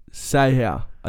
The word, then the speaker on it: Seau: Seau.